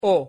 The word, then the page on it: o: Spanish